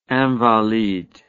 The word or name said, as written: Invalides